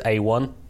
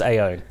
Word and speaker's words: Aione: And the horse that I would name for that upset, to try and make myself seem even better, is A1. – Oh, yeah, 33-1. – Yeah, I hate that pronunciation. Surely it's A-Own. But Connections have said it's A1, so I guess it's A1. – A-I-O-N-E, by the way, yes, if you were looking for...